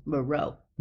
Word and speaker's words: Miró: Miró.